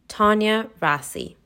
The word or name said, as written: Tania Rasie